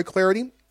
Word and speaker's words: Clardy: I'm Troy Clardy.